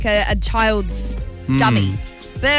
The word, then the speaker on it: Demi: Now, you read her name, spelled D-E-M-I. – Mm-hmm. – Demi, right? – Mm-hmm. – That's how it's pronounced. – Yep. – No, it's not. – How do you say her name? – Dummy. – Dummy Moore.